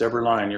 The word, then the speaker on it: Eberlein: It's Eberlein, you're correct.